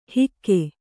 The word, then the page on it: ಹಿಕ್ಕೆ: Kannada